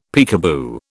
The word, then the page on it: peekaboo: British English